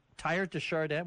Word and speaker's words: Erdoğan: Tayyip Erdoğan, try that. – It's Erdoğan? – Tayyip Erdoğan, what's that? – That's his name, that's how you say it in Turkish.